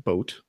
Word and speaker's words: Bote: But a guy named David Bote, I believe is how you pronounce his last name, B-O-T-E.